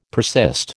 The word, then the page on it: persist: American English